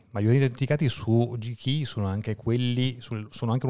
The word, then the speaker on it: GKE: E un altro problema invece era avvenuto con JKE e il JKE, non si dice così, GKE, più facile.